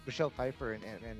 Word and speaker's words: Pfeiffer: At number 3, 62 years old from Santa Ana, California, we have Michelle Pfeiffer.